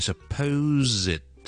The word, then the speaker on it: supposedly: 正確發音係 supposedly，我嗰個 ed 呢我會 z 埋出嚟嘅， ok， supposedly 一個 zed 嘅音嚟嘅， supposedly supposedly supposedly 就唔係就咁 supposedly 嘅， ok， supposedly。